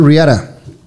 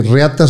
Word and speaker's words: Reata: Se llama Riara. Ándele. Porque lo pronunciamos en inglés, porque Reata se ve muy feo.